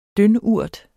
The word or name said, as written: dyndurt